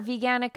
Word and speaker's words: veganic: Yeah, you are a veganic. I have a small veganic farm here. And a lot of people don't know what that term means. Thanks so much for asking about that. but anyway, it's veganic means that there's no animal input, no purposeful animal inputs.